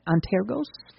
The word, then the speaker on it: Antergos: At least that's how we're pronouncing it, Antergos.